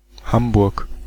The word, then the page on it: Hamburg: /ˈhambʊrk/